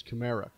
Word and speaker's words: Kamara: Uh, Kamara, excuse me, I, I, still with his pronunciation. He's told me six dozen times it's Kamara, Kamara, Kamara, Kamara.